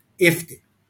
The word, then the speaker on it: Ifty: So it literally says on my LinkedIn profile, like the number 50 without the first F, Ifty.